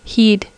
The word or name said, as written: heed